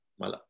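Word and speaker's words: Mappila: And I just really want to quickly ask you about one of your latest projects with, forgive me if I'm pronouncing it wrong, the Malipa community in Kerala. Is that correct? – Mapla. – Mapla. Sorry. I knew Mapla.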